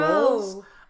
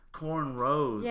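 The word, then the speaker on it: cornrows: Corn rose. – Row. – Oh, row, not row. Rolls. Oh, I'm thinking rolls, like dinner rolls. – No. – Corn rose. – Yeah. – Oh, so I've been mispronouncing it my whole life.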